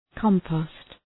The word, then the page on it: compost: {‘kɒmpəʋst}